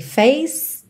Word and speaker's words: face: Atenção aí como você não pronuncia os E's do final, ok? Face to face.